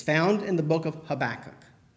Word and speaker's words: Habakkuk: Let's say it together, Habakkuk.